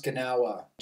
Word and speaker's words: Gnaoua: Well, it's mostly pronounced Ganawa, G N A O U A.